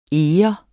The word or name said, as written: EGERNE